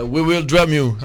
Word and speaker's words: We Will Drum You: Comment prononcerait le titre Les Fils Monkey Yann euh, We Will Drum You. Un peu comme We Will Rock You, mais à la place de rock, on a remplacé par drum.